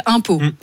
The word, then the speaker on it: Umpeau: Non, parce que la ville s'appelle Impôt. – Oui. – Ouais, moi, ça s'écrit comme les impôts qu'on paye. – Non, ça s'écrit U-M-P-E-A-U.